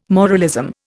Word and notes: moralism: Indian

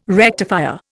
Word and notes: rectifier: Indian

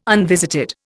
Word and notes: unvisited: Indian

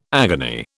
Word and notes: agony: British English

